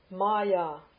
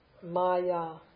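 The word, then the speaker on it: Maya (माया): Maya. Maya. Long A's. Maya.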